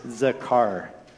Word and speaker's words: Zakar: This third word kind of rhymes with Shamar. It's Zakar.